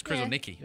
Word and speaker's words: Kruszelnicki: Oh, I've been saying it wrong. – Everyone has, I think. – Kruszelnicki. – They always put an S in this the last syllable.